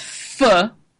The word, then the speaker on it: pho: Pho, which I pronounce pho, but I've learned it's pronounced pho.